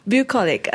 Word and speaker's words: Bucolic: Bucolic.